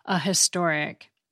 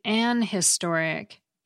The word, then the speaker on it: historic: Now, some Americans argue that it should be anhistoric, but I come down firmly on the side that says it should be a historic event. At one of my book signings many years ago now, a woman actually shook her fist at me and walked out after I said this. But there's no logical reason it should be anhistoric if you pronounce the H in historic, which most people do.